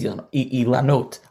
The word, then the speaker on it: ilanot: It's just beautiful, as you mentioned, to see these ilanot. I'll say them, it use the Israeli pronunciation.